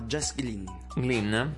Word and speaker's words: Glynne: Appunto, la ragazza si chiama Jess Glynne. – Ok, va bene, okay. Non sapevo questa pronuncia del suo cognome.